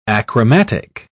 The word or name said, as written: achromatic